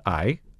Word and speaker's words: IJ: Um, it sits along what's called the Eye, Partij is the way they pronounce it in Dutch. Um, it's IJ. A lot of uh, foreigners don't know how, what to do with those two letters, but it's pronounced I in Dutch.